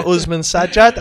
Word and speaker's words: Uzman Sajjad: Dr. Uzman Sajjad.